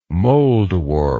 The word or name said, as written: moldwarp